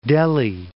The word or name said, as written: Delhi